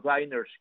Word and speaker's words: diner: del origen de los diners, que se escribe D-I-N-E-R, diner, es como si fuese dinner, la palabra esta, cena en inglés, pero en vez de con dos N's, con una sola, y por eso la otra es dinner, y esta suena diner.